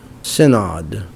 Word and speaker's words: Synod: We pray for the leaders of the Wisconsin Evangelical Lutheran, how do you pronounce that? Synod. And we pray for the second Vice President Joel Voss, and we pray for current events around the world.